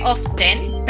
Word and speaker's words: often: Usually y often se escribe often, pero la T no suena, ¿vale? Often.